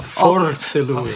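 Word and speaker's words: fors'è lui: Ah, forse lui. I'm sure I'm a little bit off. How was that? – You had to roll your R, but forse lui.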